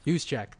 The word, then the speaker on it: Juszczyk: Juszczyk.